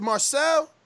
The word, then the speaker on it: Marcel: I did hear like a little noise there, but I want to thank Marcellus for coming on. How are you, brother? Am I pronouncing that right? Is it Marcel? – Marcel. Yes, yeah, right.